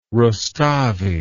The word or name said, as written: Rustavi